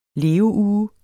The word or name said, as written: leveuge